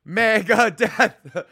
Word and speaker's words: Megadeth: And you got to fucking say, you got to look at them and let them know, we're saying Megadeth right now in three syllables. Megadeth.